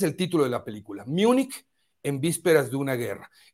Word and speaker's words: Múnich: Pero la película que les quiero recomendar el día de hoy es bien interesante y se titula, así se titula, eh, Munich, si lo pronunciamos en inglés, Múnich, si lo pronunciamos en español, y München, si lo pronunciamos en alemán.